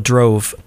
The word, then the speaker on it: Drauve: It's spelled D-R-A-U-V-E, Drove. It looks like Drauve, but it's kind of pronounced like mauve, the color mauve, which some people say mauve. But I choose to pronounce it Drove, okay?